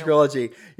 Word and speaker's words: Realogy: Those of you, I always get it confused with Realogy. It's Realogy.